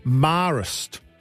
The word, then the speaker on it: Marist: Yes, I did pronounce it wrong. It's Marist College, Ashgrove. Please forgive me. That is my public school upbringing. Not saying that correctly.